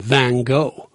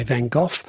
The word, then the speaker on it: Van Gogh: It depends where you come from or how you say it. You know, the Americans call him Van Gogh, and we tend to say Van Gogh, and I'm sure the Dutch say something more like Van Gogh.